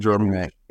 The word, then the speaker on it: Jarmusch: I will add on and say there was, and correct me how I pronounce this, guys, Jim Jarmusch, Coffee and Cigarettes. I felt a little sprinkles of that type of dialogue. – Definitely Jim Jarmusch.